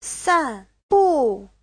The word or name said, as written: san4bu4